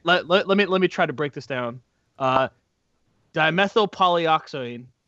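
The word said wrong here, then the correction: dimethylpolysiloxane: There's an L in there. I think you're missing the L. – Oh, my God. I have to slap it out on my hand by the syllables, like dimethyl polysiloxane.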